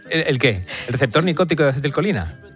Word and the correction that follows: nicotínico: Bueno, lo he dicho mal, es nicotínico.